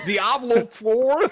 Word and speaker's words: Diablo: Number three on the Roper Report, Diablo 4. Why did I say it like that? I have no idea. Diablo 4.